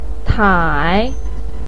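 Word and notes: tai: tone 3

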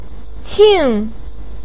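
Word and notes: qing4: tone 4

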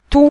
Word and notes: tu: tu5

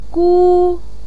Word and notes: gu1: pinyin

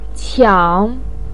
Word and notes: qiang: 3

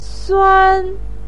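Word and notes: suan: tone 1

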